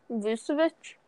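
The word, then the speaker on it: Vučević: I don't know how to say his last name. – Vucevic? – Yeah.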